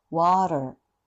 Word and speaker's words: water: これはフラップっていうのが、 2 つも出てくるので、とっても難しいんですが、フラップっていうのは、一番、典型的なのが、 water、水の、 water。 T や D がこの音になるので、決して、ラリルレロとか L ではないので、 L のように発音してもらうと、ダメなんですね。